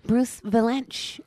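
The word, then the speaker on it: Bruce Vilanch: Bruce Valanche.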